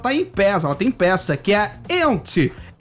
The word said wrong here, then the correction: Yentl: Ent in Concert. – Yentl. – Yentl. Perdão, eu vou fazer com pronúncia Yentl in Concert.